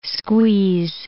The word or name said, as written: squeeze